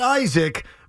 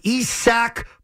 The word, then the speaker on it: Isak: And that is not Isaac, but Isak Paredes.